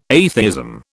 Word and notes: atheism: British English

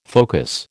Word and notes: focus: American English

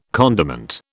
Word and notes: condiment: British English

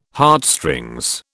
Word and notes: Heartstrings: British English

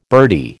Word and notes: birdie: American English

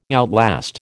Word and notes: outlast: American English

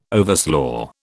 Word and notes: overslaugh: British English

